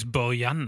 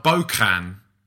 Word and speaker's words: Bojan: Is it not Bokan? Because don't they pronounce their J's as K's? – No, because I'm not... I think his name isn't a traditional Catalan name. I think it comes from elsewhere, which is why it's not the way in which you think it's going to be pronounced.